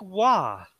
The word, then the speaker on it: Roy: Patrick Roy for us Americans that don't want to pronounce it Waugh.